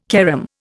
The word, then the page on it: carom: Indian